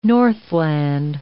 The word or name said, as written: northland